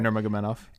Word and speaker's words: Nurmagomedov: Nurmagomedov.